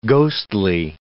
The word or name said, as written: ghostly